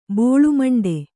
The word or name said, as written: ಬೋಳುಮಂಡೆ